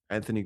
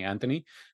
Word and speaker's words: Anthony: I've only ever first heard the H being pronounced when I moved to Canada, and some people were saying Anthony. And I'm like, what are you talking about? – I think in the Queen's English, she says TH.